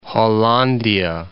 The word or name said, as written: Hollandia